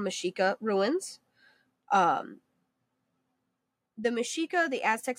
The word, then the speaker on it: Mexica: Again, Mexica, like I said, is spelled like Mexica. M-E-X.